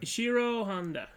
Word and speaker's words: Ishiro Honda: Ishiro Honda.